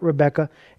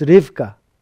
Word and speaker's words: Rivka: It's not Rebekah, it's Rivka. That is her name. You see how different it is. It's Rivka or Rebecca, but it really would be pronounced Rivka.